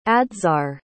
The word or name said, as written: adzhar